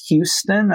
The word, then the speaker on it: Huston: And they named it Samuel Huston College. It's now pronounced Samuel Houston. I've always sort of wondered if the Texans just thought it was a misspelling of Sam Houston, but it's really Huston.